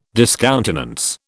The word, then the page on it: discountenance: British English